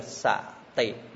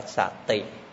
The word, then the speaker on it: sati: Niệm, niệm phiên âm từ tiếng Hán, tiếng Bali là xạ tị, đọc là xạ tị, dịch là niệm.